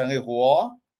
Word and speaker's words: Charleroi: Então, Charleroi, da onde eles vão sair, é a 30ª vez Charleroi, que é a pronúncia Charleroi,